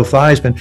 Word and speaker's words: Theismann: But we think by just simply changing the pronunciation of your last name from Theismann to Theismann to rhyme with Heisman, we can get you that trophy. And so that's how I became Joe Theismann.